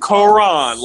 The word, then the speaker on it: Karon: K-A-R-O-N. – God damn it. Not Karen, not Karan, not Karan, none of that bullshit. – Karan, like the Bible, but it's spelled K-A-R-O-N, and then Collins is C-O-L-L-I-N-S 3.